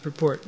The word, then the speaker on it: purport: Purport, the noun, is pronounced purport. No, it is stressed on the first syllable.